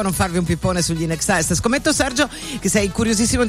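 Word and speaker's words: INXS: Allora, il nome della famosa band australiana In Excess si pronuncia appunto con come la parola inglese in eccesso, in excess,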